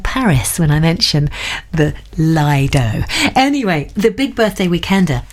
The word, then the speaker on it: Lido: I mentioned the word Lido, and Matt's emailed in and told me, Laura, no, it's Lido, as in D-O-U-G-H. You've got to pronounce it like that.